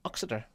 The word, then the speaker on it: Uttoxeter: But I'm told that there's a different way of pronouncing Uttoxeter as well. – Really? – If you happen to be from the local farming community, call it Uttoxeter.